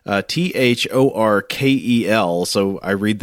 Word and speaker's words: Thorkel: Uh, so it's like Sar Do, no Mister accent on the Do. Here we get Thor Kel, no Mister. It's Doctor accent on the Kel. Doctor Thor Kel.